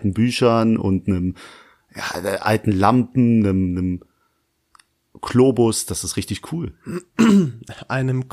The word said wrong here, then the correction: Globus: Nein, da, da, heißt das nicht ein, ein Kloben? Da, wo du herkommst, spricht man das so aus, ne? äh, ja, ich, genau. Globus war übrigens gemeint, aber.